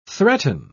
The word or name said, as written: threaten